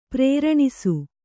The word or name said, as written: ಪ್ರೇರಣಿಸು